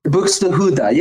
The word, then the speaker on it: Buxtehude: But the song, Dietrich Buxtehude. – Buxtehude. Yeah, you said it right.